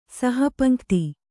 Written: ಸಹಪಂಕ್ತಿ

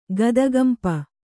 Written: ಗದಗಂಪ

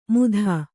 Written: ಮೂಧ

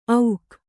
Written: ಔಕ್

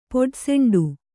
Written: ಪೊಡಸೆಂಡು

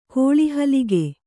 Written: ಕೋಳಿಹಲಿಗೆ

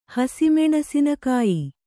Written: ಹಸಿಮೆಣಸಿನಕಾಯಿ

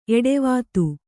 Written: ಎಡೆವಾತು

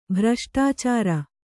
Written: ಭ್ರಷ್ಟಾಚಾರಿ

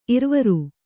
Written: ಇರವಾರ

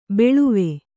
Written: ಬಾಳುವೆ

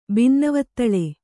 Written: ಬಿನ್ನವತ್ತಳೆ

